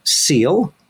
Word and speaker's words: SEAL: We have Kim Price, who is Manager of Social Emotional Academic Learning, and that's an acronym that is pronounced SEAL, at Denver Public Schools.